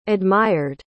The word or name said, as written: admired